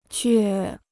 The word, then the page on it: 倔: jué